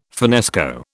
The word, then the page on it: finnesko: British English